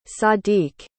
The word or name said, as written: sadiq